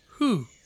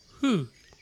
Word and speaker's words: who: さあ who の発音なんですけど、皆さんちょっと who と言ってみてください。 who、 who、あのね、ふうというときに、多くの日本人はふう、口が意外とね、動いてない。